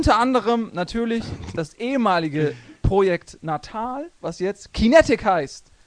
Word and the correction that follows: Kinect: du hast das schon falsch Mäh. gesagt. Noob. Das habe ich falsch gesagt. Kintech. – Kintech. – Kinect. Kinect, verdammt. Kinect.